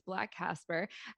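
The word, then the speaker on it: BLXCK CXSPER: I'll mention that Black Casper, the spelling, in case you want to look this up, it's B-L-X-C-K and C-X-S-P-E-R. It's not pronounced anywhere different. It's Black Casper, the play that they're presenting.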